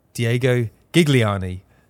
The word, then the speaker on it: Gigliani: I am joined by another very, very special guest, Diego Gigliani, president of St. Louis City SC. Welcome to the podcast. How are you doing today? – Thanks, very much. You pronounced my last name perfectly.